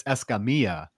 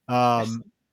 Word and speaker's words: Escamilla: Okay, so if you want to be technical and pronounce it the correct Hispanic way, it's Escamilla. Escamilla. Oh. Because, you know, double L's in Spanish are Y's.